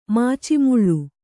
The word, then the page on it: ಮಾಚಿಮುಳ್ಳು: kannada